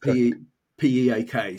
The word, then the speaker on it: PIIQ: So it's PIIQ, but pronounced P P-E-A-K sort of thing for pronunciation.